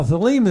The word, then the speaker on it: Theleme: Now, Thelem is spelled T-H-E-L-E-M-E, Thelem,